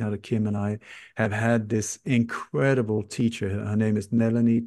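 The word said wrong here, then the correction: Nalanie: Chalaram. Nalani, it's spelled N-A-L-A-N-I-E, Nalani Chalaram.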